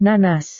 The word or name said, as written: nanas